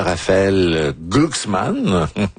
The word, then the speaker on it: Raphaël Glucksmann: Et Ségolène Royal a lancé dimanche une première salve contre le candidat Raphaël Glucksmann.